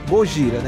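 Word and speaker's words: Gojira (ゴジラ): E eu fiz um pouco aí um apanhado aí, que o nome Godzilla, originalmente, ele é pronunciado como Gojira, né?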